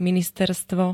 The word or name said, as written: ministerstvo